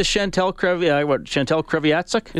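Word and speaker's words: Chantal Kreviazuk: Is he still married to Chantel Kreviatsuk? – As far as I know. – Is that her name? Did I say it right? – I think you nailed it.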